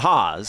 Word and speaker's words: Haas: First pitch to Hawes, strike one. And no, it is not spelled H-A-W-S like TJ and Tyler or Marty. It is spelled H-A-A-S, but pronounced Hawes.